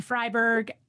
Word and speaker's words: Friberg: On Instagram, I'm Patricia Freiberg. My last name is spelled F-R-I-B-E-R-G. On Facebook, this might surprise you, but it is Patricia Freiberg Health and Fitness.